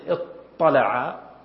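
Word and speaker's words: اطّلع: لكن يصعب نطق التاء، تأثرت بالطاء المفخمة المستعلية التي من حروف الإطباق فقلبت التاء إلى أختها التي هي الطاء التي من مخرجها، أصبح فيه طاءً اطلع، ثم أدغمت وأصبحت طاءً مشددة. أيضا ضعنا مثلا، ضعنا افتعل من ضعنا اضطعنا الضاء إذا كانت فعل لما اكتبها.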